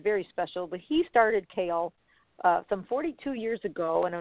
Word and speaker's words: CAAEL: I see it spelled C-A-A-E-L. How do we pronounce that? – Correct. So we pronounce it kale, like the vegetable that kale.